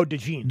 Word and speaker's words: DeJean: Is it LeJune? How do you say it? The white corner? – Oh, DeJean.